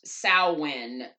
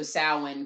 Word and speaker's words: Samhain: Even though it's pronounced Samhain, but it's spelled S-A-M-H-A-I-N. – Samhain.